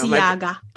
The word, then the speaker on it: Balenciaga: And we don't see him like wearing Bella's Genzi or whatever tennis shoes. I probably mispronounced it because I really don't know. – Balenciaga.